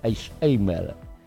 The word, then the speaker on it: Alzheimer: Alzheimer y Parkinson.